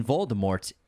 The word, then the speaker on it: Voldemort: Author J.K. Rowling previously stated that the T in Voldemort is silent, as it is in the French word for death, mort.